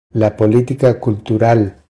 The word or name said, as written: La politica cultural